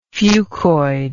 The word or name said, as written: fucous